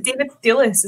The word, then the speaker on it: Thewlis: I thought, eh, is it David Thewlis? Is that how you say his name? – Yeah.